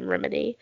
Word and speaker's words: Remini: Leah Remini, which is how you say her name, Remini.